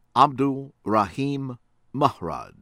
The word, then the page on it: Abdul Rahim Mrad: American English